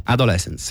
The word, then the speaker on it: Adolescence: Si pronuncia così? – Adolescence, sì. – Adolescence. – Sì. – Io ho detto adolescence. – Ma l'hai detto bene.